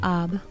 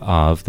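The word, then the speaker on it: Ab (אב): Ab. – Perfect. Ab or Av, the B or V sound is either one.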